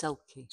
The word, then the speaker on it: Selkie: So it says, a Selkie and a stranger. – It's Selkie.